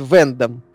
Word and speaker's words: Вендом: Голову, кстати, зовут Вендом. – Mm-hmm. – На ударение на первый слог и пишется в одно слово.